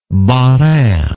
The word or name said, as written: Bas Rhin